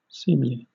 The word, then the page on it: seemly: British English